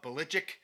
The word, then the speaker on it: Belichick: Bill Belichick, or Belichick, as he says, that's the way you're supposed to announce it, or pronounce it, excuse me. But Belichick, because that's the way everybody else does it, he's greatest coach all time.